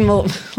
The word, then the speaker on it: Mullally: Megan Mullally, like Shillelagh? Is that how her last name is pronounced? – As far as I know.